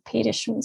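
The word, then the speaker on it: Petterson: It's written Peterson, but apparently they pronounce it Petersen.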